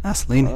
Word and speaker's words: Assouline: Assouline.